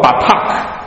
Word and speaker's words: batak: 大家注意到这里面的这个无虑，在希伯来文当中读作 batak，他在这个第九节、第十节、第十一节多次的出现。